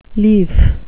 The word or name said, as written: lief